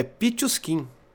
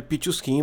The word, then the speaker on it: Pichushkin: Aí eu fui consultar como é que fala, é Pichuskin. Aí o nome dele, Alexander Pichuskin.